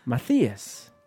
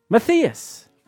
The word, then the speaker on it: Matthias: It's pronounced Matthias. – It's an H. – T-H. Come on, you blew it again. You blew it again. – Matthias. Matthias. Matthias. – You got it.